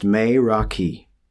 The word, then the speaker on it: meraki: So I believe the word is pronounced meraki,